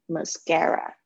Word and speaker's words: mascara: mascara 啊，这个虽然是 a 结尾，记得是 a 的声音。mascara 不要念成 mascara，r 不是 r。 mascaras，OK，mascaras，稍微把它变成是有点接近 R 的声音，mascaras，right？